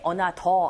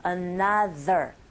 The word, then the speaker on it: another: Another 할 때 another 하지 마시고 TH 발음을 해주셔야 돼요. 그러니까 입모양을 잡아주셔야 된다는 거죠. Another, another, another.